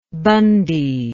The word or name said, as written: bundy